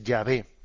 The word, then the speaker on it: Yahvé: Luego los estudios filológicos pues tuvieron más conciencia, avanzaron más y llegaron a entender que la pronunciación más correcta, más que Jehová, es Yahvé.